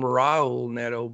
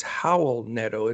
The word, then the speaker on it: Raul: I always called him Raul Neto, but he's from Spain, and it's Howl Neto. It's the R's are pronounced like H's.